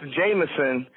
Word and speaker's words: JMSN: Hey, man, it's stylized JMSN, but it's pronounced Jameson. Jameson is the artist's name.